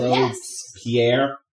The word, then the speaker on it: Robespierre: You're asking the wrong person, so Robespierre.